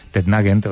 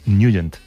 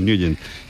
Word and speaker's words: Nugent: Siempre lo hemos llamado en el barrio Tednagent o Tednagent. – Claro, yo era de Tednagent. – Pero la pronunciación correcta es Nugent. – Te fuiste a Washington y descubriste que era Nugent.